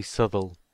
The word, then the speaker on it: Southall: Yes, it's South All.